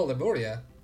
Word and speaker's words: Lemuria: Oh, Lemuria.